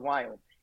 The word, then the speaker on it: Viome: So most people who may not know, I started a healthcare company called Viome. And as an Indian guy, I cannot pronounce the word V. So it's V as in Victor.